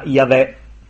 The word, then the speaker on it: Yahweh: È quello che suona Yahweh. Si scrive con quattro consonanti.